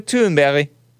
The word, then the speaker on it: Thunberg: Dom Lucre posted Greta Thunberg. That's how you say it in Swedish, I heard.